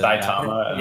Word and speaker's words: Saitama: You don't know Satima? That's how you pronounce his name, right? – Yeah. – That would actually be really Saitama.